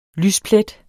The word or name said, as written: LYSPLETTEN